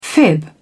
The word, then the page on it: fib: British English